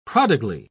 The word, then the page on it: prodigally: British English